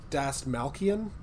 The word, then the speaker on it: Dastmalchian: David Dastmalchian. Is that how you say his name? – Yeah, he's in.